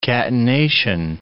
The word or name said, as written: catenation